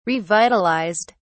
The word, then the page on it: revitalised: British English